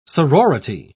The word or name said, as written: sorority